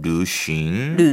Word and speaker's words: 旅行: 여행이란 발음이 조금 어려워요. – 네. – 자, 입 모양을 루로 하고요. 루. 근데 발음은 루루루예요. – 루루루싱. – 루싱. – 루싱. – 루싱. 싱 할 때는 풀어주면 돼요.